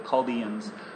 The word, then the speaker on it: Chaldeans: What he says is he's sending the Chaldeans, or the Chaldeans, Josiah would correct me in the pronunciation. It's the Chaldeans, which is the Babylonians.